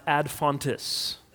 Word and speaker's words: ad fontes: Now, in traditional academia, there is a Latin expression that is it's pronounced ad fontes.